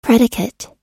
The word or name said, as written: predicate